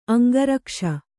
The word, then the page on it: ಅಂಗರಕ್ಷೆ: aŋgarakṣe